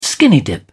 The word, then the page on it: skinny-dip: British English